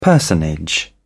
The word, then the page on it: personage: British English